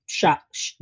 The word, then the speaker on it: chakra: So what are the other chakras? Am I saying it correctly? – Chakra. You're saying it right.